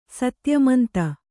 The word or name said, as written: ಸತ್ಯಮಂತ